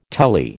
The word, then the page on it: telly: American English